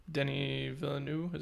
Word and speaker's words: Denis Villeneuve: And from what I've heard, Denis Villeneuve, is that how you say his name? – Yeah.